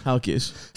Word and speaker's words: Halkias: How do you say it when you say it? – Halkias is in Greek,